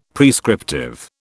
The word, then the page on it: prescriptive: British English